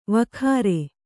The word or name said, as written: ವಕ್ರೆ